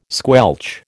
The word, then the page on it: squelch: American English